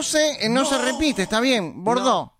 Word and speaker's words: Bordeaux: Bordó.